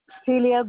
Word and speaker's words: Celia: Celia Guzman. – Spell that first name for me. – C-E-L-I-A. – G-U-Z.